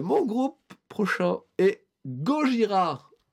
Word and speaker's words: Gojira: Du coup, le groupe change pour Gojira, qui est la prononciation japonaise de l'animal.